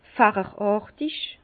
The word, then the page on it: facheràrtig: Bas-Rhin; Herrlisheim